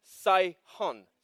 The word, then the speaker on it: Sihon: The way you pronounce that is Sihon.